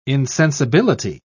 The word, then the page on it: insensibility: British English